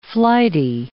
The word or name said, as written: flighty